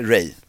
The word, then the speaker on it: Ralph: Ja, han är Ray. Uttalas det.